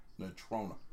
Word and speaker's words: Natrona: From Nathana County High School. – Natrona. – Well, all right. – Here's Wyoming. – Which rhymes with Corona.